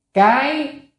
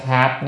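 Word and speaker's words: cái (蓋): Cái chữ này có đọc hai âm, cái và hạt, nhưng ở đây nên phải đọc là cái, mấy âm là cái mới đúng.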